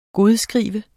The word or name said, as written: godskrive